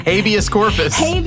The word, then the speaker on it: habeas corpus: Wrong emphasis on the wrong syllable. – You want to start that over? – Yes. – Okay. – Thus possessing the ability to invoke habeas corpus.